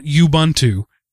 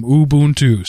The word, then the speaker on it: Ubuntu: There is a correct way to say it. All three groups of O's have the same sound. It's not Ubuntu, where each one has its own separate sound. They're all the same.